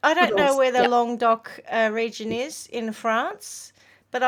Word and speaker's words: Languedoc: It's from the Languedoc region in Vietnam. – No, it's Languedoc. Yeah, I think that's how you say it.